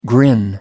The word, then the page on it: grin: American English